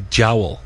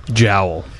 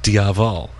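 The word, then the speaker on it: diabhal: Well, actually, if you're pronouncing it in Irish, it would be Jowl. – Jowl. – Right. – Okay. – Like that thing on your... Jowl. – Yeah, exactly. Yeah, the thing on your face, right? Yeah. Yeah, if you're pronouncing it in kind of American English Irish, it would be kind of just Diavol, right? – You know, Diablo.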